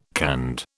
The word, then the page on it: canned: British English